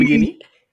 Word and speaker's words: Guinea: I mean, specifically for you two, I know you guys are Muslim, and I'm not sure are both your families from Guinea. I hope I'm pronouncing that right. – Yeah, it is Guinea, but no.